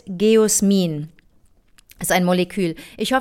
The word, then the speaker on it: Geosmin: G-E-O, Geo, dann ein S und dann M-I-N. Wie würdest du das aussprechen? – G-O-S. – G-E-O-S, M-I-N. – Geosmin. – Geosmin, okay, dann habe ich es richtig ausgesprochen.